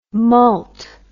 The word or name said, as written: mult